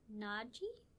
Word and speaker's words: Naji: So the main characters throughout this graphic novel are Ibrahim, who is the father, his wife, their son Naji, I think I'm saying it right, N-A-J-I, I think it's Naji, and their family.